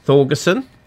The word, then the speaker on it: Thorgerson: which was led by a guy called Storm Thorgerson.